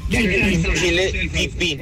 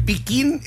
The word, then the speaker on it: piquín: ¿Se pronuncia chile piquín o chile pipín? – El piquín es el piquín.